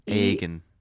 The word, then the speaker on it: Eoghan: Egon. – E-O-G-H-A-N. – Egan. – Egan. Egan. – It's Irish.